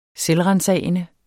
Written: selvransagende

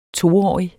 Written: toårig

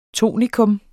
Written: tonika

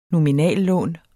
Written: nominal

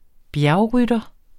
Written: bjergrytter

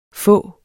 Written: få

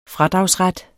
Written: fradragsret

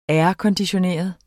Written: airconditioneret

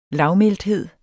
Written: lavmælthed

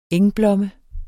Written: engblomme

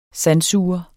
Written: sandsuger

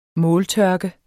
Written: måltørke

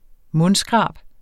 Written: mundskrab

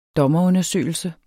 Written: dommerundersøgelse

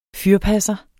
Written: fyrpasser

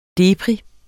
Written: deprim

